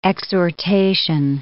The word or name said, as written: exhortation